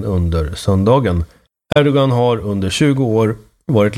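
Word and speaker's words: Erdoğan: Erdogan, det uttalas ju Erdogan. Det har man lärt sig. Med det här special G-et är det kanske. Och han har också Kılıçdaroğlu.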